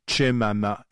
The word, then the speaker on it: Chemama: C'est publié chez Dargo par Alice Chemama, exactement comme ça se prononce, C-H-E-M-A-M-A. Alors, la chose est bien simple.